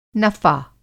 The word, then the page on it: नफ़ा: نفع (نفَع)